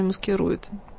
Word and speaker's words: маскирует: Он находился в настоящей клинической депрессии, а клиническая депрессия часто маскирует себя алкоголизмом. Маскирует — это, кстати, медицинское ударение. По-русски мы бы сказали «маскирует».